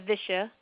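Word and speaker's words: visha (विष): So when your ama is sitting in the channel stuck there and it starts to ferment, it becomes what's called ama visha. This is spelled V as in Victor, I-S-H-A.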